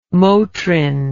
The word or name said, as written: motrin